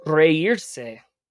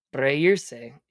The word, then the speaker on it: reírse: Uh, your first word is reírse. Reírse.